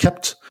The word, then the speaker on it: kpt: KEPT is not an acronym, so it doesn't actually stand for anything. It's pronounced KEPT.